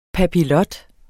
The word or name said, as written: papillot